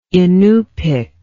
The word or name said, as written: Inupik